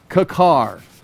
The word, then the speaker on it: kikkar: The word is kakar. And I've got all kinds of maps there for you to look at. Kakar, kakar. Kakar, I spell that K-I-K-K-A-R. I spelled it correctly, but I spelled it very sloppy. So let me try it again. K-I-K-K-A-R. The word itself means, I've got it written right there.